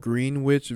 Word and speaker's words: Greenwich: Greenwich Village. My girlfriend and I went back and forth about Greenwich Village for so long because we were trying to debate whether it was called Greenwich Village or Greenwich.